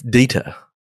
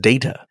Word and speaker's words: Data: And he's like, it's Data. And she's like, what? And he's like, it's pronounced Data.